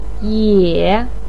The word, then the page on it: ye: 3